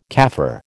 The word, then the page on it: Kaffir: American English